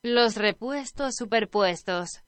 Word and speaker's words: Los Repuestos Superpuestos: So I don't know if the band translates into Overlapping Spares, but the band pronounced better than what I was pronouncing is Los Repuestos Superpuestos.